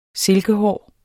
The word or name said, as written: silkehåret